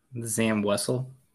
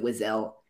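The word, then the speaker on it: Wesell: And I found out yesterday that Charlie Ashby pronounces it Wizel, and I'm going to get him on In a Galaxy just so I can hear him say he thinks it's Wizel.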